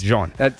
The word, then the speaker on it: Jean-Baptiste: Is it Jean or John? – I believe it's Javonte Jean-Baptiste. – Jean. – We're going with the French pronunciation because it's spelled the French way.